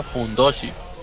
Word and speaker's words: fundoshi: y luego nos explica que la tanga de luchador de sumo se pronuncia Fundoshi, así que ahora todos sabemos lo que es un Fundoshi.